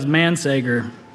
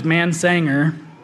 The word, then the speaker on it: Mansager: It's, uh, again, that was Mansager. And I know it's a, it's a hard name to pronounce or to read for some reason. Uh, for all my life, it's been uh, pronounced a lot of different ways. And, um, you know, I've gotten, uh, most common is Mansanger, uh, Mansinger, Man Mansager, Man uh, well, you get my drift.